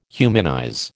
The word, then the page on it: humanize: American English